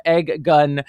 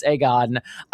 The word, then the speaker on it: Aegon: I love you, girl, but please stop calling him Egg Gun. It's Aegon.